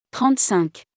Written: 35

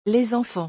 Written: Les enfants

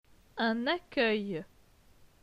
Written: Un accueil

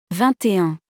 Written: 21

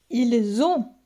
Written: Ils ont

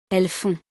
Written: elles font

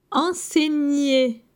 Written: Enseigner

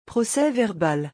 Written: procès-verbal

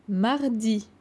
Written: Mardi

